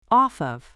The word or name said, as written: off of